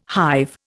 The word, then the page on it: hive: Indian